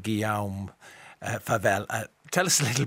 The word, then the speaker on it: Guillaume: Guillaume, like William.